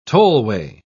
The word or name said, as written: tollway